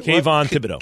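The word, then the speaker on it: Kayvon Thibodeaux: Kayvon Thibodeau.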